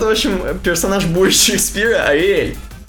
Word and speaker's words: Arial: что правильно говорить не Ареал, как здесь чувак пишет, а Ариэль.